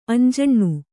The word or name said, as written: ಅಂಜನಿ